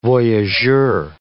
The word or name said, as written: voyageur